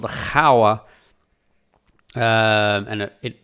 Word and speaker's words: Gouwe: The river is spelled G-O-U-W-E. So you'd look at that and go, it's the Gouwe.